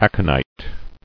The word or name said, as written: Aconite